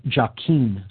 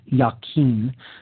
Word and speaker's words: Jachin: The pillar marked with the letter S there on the left is called the pillar of Jachin, sometimes pronounced Jachin. That's J-A-C-H-I-N.